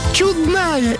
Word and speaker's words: чудная: Чудная или чудная? Какое настроение, такое ударение.